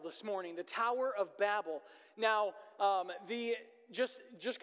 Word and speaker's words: Babel: Uh, the actual correct pronunciation in the Hebrew is Babel, um, but to make it easy on the ears, um, I will stay with with Babel as that's how we would pronounce it in our English language. Um, and if you're from England uh, or the UK area, it would be Babel. Uh, but we're just going to stick with Babel here, just just to make it easy as we walk through this.